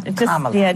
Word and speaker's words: Kamala: Kamala. – Just think of like a comma and add a la.